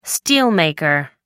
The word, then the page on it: steelmaker: American English